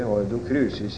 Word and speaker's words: Ordo Crucis: Man uttalte det gjerne der riktig, humanistisk latin, og sa Ordo Crucis for øvrig. Var nesten et kjennetegn på at man var intramural, som medisinerne sier, at man visste det hette Ordo Crucis i virkeligheten, etter den der eldre humanistiske uttale av latin.